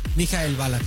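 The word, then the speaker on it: Michael Ballack: ¿Cómo se dice bien? ¿Mikhail Balak? – Mikhail Balak, sí.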